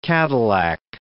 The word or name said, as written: Cadillac